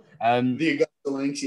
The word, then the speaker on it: Augusta Lynx: Next year, 08-09, you're with them again, also mixing it up in the East Coast with Augusta Lynx or Lion, is it? I can't say it properly. – The Augusta Lynx, yeah.